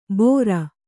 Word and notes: ಬೋರ: Kannada

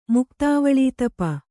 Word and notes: ಮುಕ್ತಾವಳೀತಪ: kannada

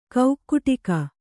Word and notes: ಕೌಕ್ಕುಟಿಕ: kaukkuṭika

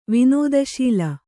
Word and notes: ವಿನೋದಶೀಲ: vinōdaśīla

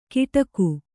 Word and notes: ಗಿಟಕು: Kannada

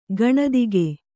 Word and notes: ಗಣಿದಿಗ: gaṇidiga

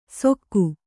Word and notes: ಸೊಕ್ಕು: kannada

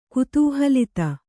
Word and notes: ಕುತೂಹಲಿತ: kutūhalita

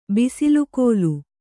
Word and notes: ಬಿಸಿಲುಗೋಲು: kannada

